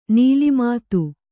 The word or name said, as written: ನೀಲಿಮಾತು